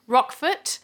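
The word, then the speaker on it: Roquefort: We've also got Sterling Holloway as Rock, how do you say the rat's name? – Rockford. – Rockfort, yep.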